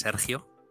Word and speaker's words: Sergio: So just to add to what you already explained, I am originally from Spain. So for those who can fake the accent, the actual pronunciation of my name is Sergio. Good luck with that. I think if you speak Bahasa, you can probably do it.